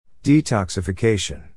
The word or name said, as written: detoxification